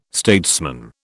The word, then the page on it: statesman: British English